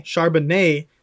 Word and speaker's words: Charbonnet: Charbonnet.